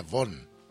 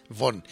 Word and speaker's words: Vaughan: Que se pronuncia Von. Von. – Como James. – Pero con V. Con V.